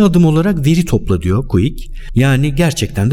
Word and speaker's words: Kwik: Sınırlar varsa senin için diyor, quick, quick, bir şekilde bu soyisim de K-W-I-K Quick diye okunuyor herhalde.